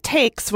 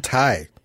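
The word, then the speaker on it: Taix: But on September 5th, at one of the most controversial pronunciations in Los Angeles, but I'm going to go with Tay. – they called it Takes when I called. – Takes. – So. Hot Takes. – Hot Takes. – I've heard Thai. – Thai. Oh, my God. There are articles about how to pronounce it. It's T-A-I-X.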